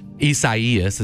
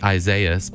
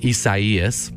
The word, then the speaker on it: Isaias: And so, you know, we're really thinking about and remembering families in the path of Hurricane Isaias, is how you pronounce it. You'd think it'd be like Isaias, but it's a Spanish pronunciation, Isaias.